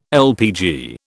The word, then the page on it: LPG: British English